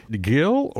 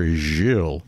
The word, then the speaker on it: Gil: And I need a little help with pronunciation, so I'll just dive in and say it's Gil or Jill? – Gil Bar-Sela, you got it. – Gil and Laurie Archbold.